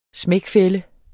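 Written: smækfælde

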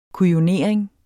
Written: kujonering

